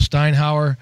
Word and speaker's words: Steinauer: So I, I also got a clip of him talking about uh, Coach Steinauer, I believe is how we pronounce it. Uh, but here's what Tedford had to say about him. Our defensive coordinator, Orlando Steinauer, um, came from Canada uh, in the CFL.